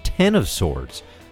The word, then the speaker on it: X of Swords: At this week's 2020 Chicago Comic-Con, aka C2E2, Jonathan Hickman and the X-Men team announced the X-Men's first Dawn of X crossover, X of Swords, no, pronounced 10 of Swords.